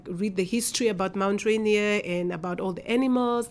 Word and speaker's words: Rainier: We went to Mount Rainier.